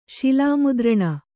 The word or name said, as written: ಶಿಲಾಮುದ್ರಣ